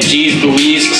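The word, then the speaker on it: Geesluis: So if you don't know how to spell that, for you uneducated motherfuckers, it's G-E-E-S-L-U-I-S. Don't put no fucking O in that bitch. – It's not Z. – It ain't no Z. – It's not G's Louis. It's not none of that. It's G's Louise. G-E-E-S. – Wrong bite. – L-U-I-S.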